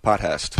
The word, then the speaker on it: Potthast: Potthast.